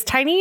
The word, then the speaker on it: Teine: Tiny is spelled T-E-I-N-E.